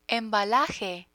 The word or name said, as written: embalaje